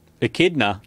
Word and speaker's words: echidna: Kichna? What is it? How do you say it? – Echidna.